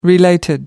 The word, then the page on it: related: American English